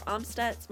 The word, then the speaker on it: Amstutz: You can find me on all social media platforms at Sandra Amstutz. My last name is spelled AMSTUTZ.